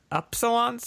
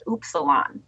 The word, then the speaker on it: Upsilon: We mispronounce it on purpose. It's actually supposed to be pronounced Upsilon. But knowing most English speakers, they would type it out with OOPS. – That's smart. Yeah. – So we're like Upsilon because it's spelled up.